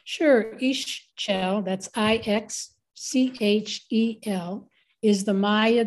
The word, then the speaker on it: Ixchel: So speaking of divinities, you have a relationship with Ixchel, is that correct? Is that how you pronounce her name? – Yes, Ixchel.